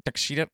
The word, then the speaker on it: Takeshita: So Will Ospreay is going to take Takeshita. Is that how you say his name? – Takeshita.